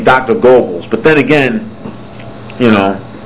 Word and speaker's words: Goebbels: I pronounce Dr. Goebbels, it's spelled G-O-E-B-B-E-L-S. Now, if you know your German grammar, G-O-E is the same as writing G-O with an umlaut over the O, taking away the E, and B-B-E-L-S.